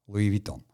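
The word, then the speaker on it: Louis Vuitton: как сейчас ходят с сумками Луи Вуитон. Луи Витон, да, правильно говорить.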